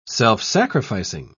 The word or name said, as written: self-sacrificing